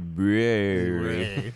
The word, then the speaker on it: Bruery: And we've been big fans of the brewery, or as we like to refer to it as the brewery, because it is spelled B-R-U-E-R-Y.